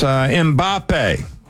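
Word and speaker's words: Mbappé: Yeah, France, Mbappe. Did I say that correctly? – Yep, that's correct.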